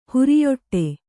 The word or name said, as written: ಹೊರಿಯೊಟ್ಟೆ